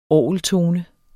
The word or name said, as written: orgeltone